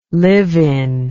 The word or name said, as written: live-in